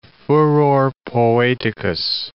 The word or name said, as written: furor poeticus